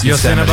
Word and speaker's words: Yosemite: Yosemite.